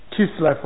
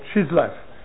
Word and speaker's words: Kislev: It was to be on the 25th of the Jewish month Kislev or Chislev, or sometimes pronounced Chislu.